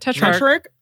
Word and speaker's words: tetrarch: At that time, Herod the Tetrarch? – Yeah, you got it. Tetrarch? – Oh, yeah. Yay. Mm-hmm. What does that mean? – So a tetrarch is basically a subordinate ruler.